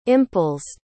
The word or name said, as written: impulsed